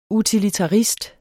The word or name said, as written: utilitarist